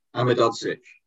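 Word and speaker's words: Ahmedhodžić: Ahmedodzic.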